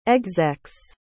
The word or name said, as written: execs